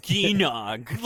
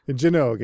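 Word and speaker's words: Gynoug: I was like, Ginogue. Like, Jinogue is how it's supposed to be pronounced according to the katakana.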